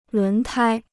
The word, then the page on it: 轮胎: lún tāi